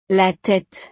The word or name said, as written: la tete